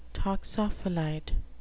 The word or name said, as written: toxophilite